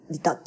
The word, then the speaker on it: 利他: 利益の他って書いて利他って読む、利他って読むはずなんですけども。